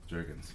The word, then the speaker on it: Jurgens: So uh, we're about to record the 73rd episode of Rank N Review, and I have in my filthy garage Erich Jurgens. Jurgens, I said it right. Cool.